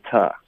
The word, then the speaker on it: to: To 要弱读成 ta。